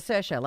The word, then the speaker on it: Saoirse: Rhymes with inertia. she's a huge actress. – Saoirse Ronan. She always says Saoirse, like Inertia. I'm like, oh. – Right. – And it's like S-O-I-R-C-H backwards X.